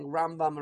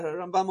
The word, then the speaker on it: Rambam: So if it's the Rambam, emphasis on the first half, as opposed to the Ramban, emphasis on the second half.